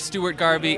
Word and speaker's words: Stewart Garvey: I pretty much only just have Instagram at Stuart Garvey, S-T-E-W-A-R-T-G-A-R-V-E-Y.